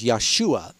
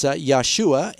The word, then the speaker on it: Yeshua: Jesus' name was Yeshua. That's the more correct pronunciation if you speak Hebrew. Greek pronunciation is Jesus. It's Yeshua, and he was the son of Joseph of Nazareth.